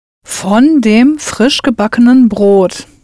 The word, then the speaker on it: von: For of, in this sentence, you'll use von, V-O-N, pronounced von.